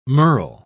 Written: merl

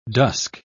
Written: dusk

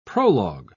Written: prologue

